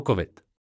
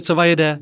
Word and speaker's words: COVID: Druhý je karikatura nacisty, který ani neumí vyslovit slovo COVID. Krize cova jede 19. Covid 19 cova jede? Covid? Vážně?